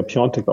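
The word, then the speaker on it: Piątek: Der ja Piontek ausgesprochen wird, obwohl er Piatek geschrieben wird.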